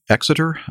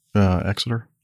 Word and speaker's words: Exeter: I'm saying that right, am I? Exeter? – Yes, you are.